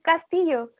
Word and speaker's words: Castillo: And when you think about pronunciating it, you can say, if you think about yo-yo, you can say Annie Castillo-yo or Annie Castillo, because you don't have the double L in English very often, but you pronounce it like you pronounce yo-yo, Annie Castillo.